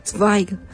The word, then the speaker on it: Zweig: Y Zweig, que es Z-W-E-I-G, pero sí, se pronuncia como dice. – Yo me fijé cómo se pronunciaba y decían que se pronunciaba, me corregirán, por favor, Zweig. – En alemán, Zweig.